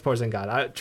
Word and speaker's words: Porzingis: I know, it's Porzingis.